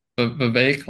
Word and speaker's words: Vivek: Vivek, like cake.